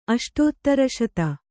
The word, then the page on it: ಅಷ್ಟೋತ್ತರಶತ: Kannada